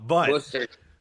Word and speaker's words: Worcester: But Worcester.